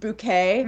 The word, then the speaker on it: Bucket: And like her last name is Bucket, but she says it's pronounced bouquet.